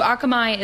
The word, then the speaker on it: Akamai: Akamai, am I saying it right? – Yeah. Akamai. Mm-hmm.